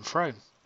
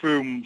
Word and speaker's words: Frome: And how do you even pronounce it? I always call them Frome. – Oh, well, the locals call it Froome, so we don't want to antagonise the lovely people down there.